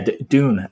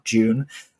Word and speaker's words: Dune: But yeah, Dune apparently is, I'm told, how you say it, not June.